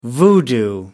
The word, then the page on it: voodoo: American English